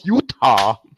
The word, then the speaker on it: Utah: I still can't come over how you pronounce Utah. – Utah. – Utah. Yeah. – It's like the letter U, and then you just add ta to it.